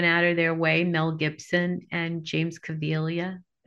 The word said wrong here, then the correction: Caviezel: Cav- how do I pronounce his name? – I can't say it either, to be honest with you. Caviezel. Jim Caviezel. Caviezel.